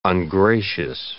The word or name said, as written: ungracious